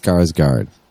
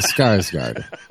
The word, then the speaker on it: Skarsgård: Like, you're trying to trap my fucking guy, Stellan Skarsgård. Skarsgård? Skarsgård. Skarsgård.